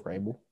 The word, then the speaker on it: Vrabel: How do you say his name? Vrabel? Vrabel? – Vrabel. Vrabel. V-R-A-B-E-L.